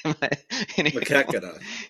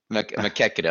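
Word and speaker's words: Maquoketa: Anyone know how to pronounce that? – Makuketa. – Makuketa. Okay, I'll take that.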